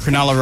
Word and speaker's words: Cronulla: Cronulla? Is that how you say it? – Cronulla riots back in 2005.